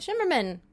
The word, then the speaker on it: Shimerman: Shimerman.